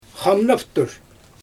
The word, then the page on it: xamnaptur: Xyzyl